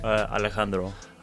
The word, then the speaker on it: Alejandro: Alejandro.